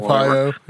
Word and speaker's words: Arpaio: You know, he just pardoned the sheriff guy, his name I can't pronounce, Ario. – Yeah, Joe Arpaio.